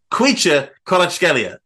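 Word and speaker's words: Khvicha Kvaratskhelia: I spent ages mastering saying Kvitcha Kvaratskhelia. And then I find out that the V is a W, pronounced as a W. It's Kvitcha Kvaratskhelia.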